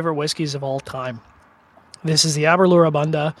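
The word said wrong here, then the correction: A'bunadh: Or Abuna, sorry, terrible, terrible Gaelic pronunciation there.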